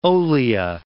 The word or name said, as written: olea